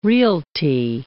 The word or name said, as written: realty